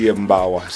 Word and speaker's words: Yembawa: Vamos a escuchar este tercer tema de New World, New Life, que se llama Yembawa, no sé si lo he pronunciado bien. – Sí, Yembawa, sí.